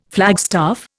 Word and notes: flagstaff: Indian

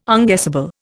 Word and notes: unguessable: Indian

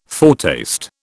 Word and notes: foretaste: British English

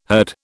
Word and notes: herd: British English

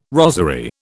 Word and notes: rosery: British English

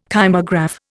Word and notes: kymograph: Indian

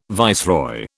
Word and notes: viceroy: British English

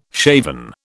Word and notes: shaven: British English

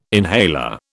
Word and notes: inhaler: British English

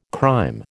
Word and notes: crème: American English

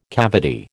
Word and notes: cavity: American English

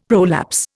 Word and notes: prolapse: Indian

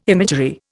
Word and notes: imagery: Indian

